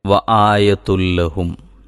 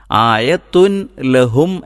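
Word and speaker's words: വ ആയത്തുൽ ലഹും (وَآيَةٌ لَهُمْ): ആയത്തുൻ ലഹും എന്നല്ല, മറിച്ച് വ ആയത്തുൽ ലഹും ഒന്ന് പറഞ്ഞു നോക്കിയാൽ നമുക്ക് പിന്നീട് എല്ലാ ഭാഗത്തും ശ്രദ്ധിക്കാൻ പറ്റും.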